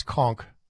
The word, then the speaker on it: conch: Yeah, it's a pronunciation says conch. I think it's conch shell. Conch shell, conch shell, conch shell. It's got to be conch shell.